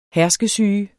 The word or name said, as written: herskesyge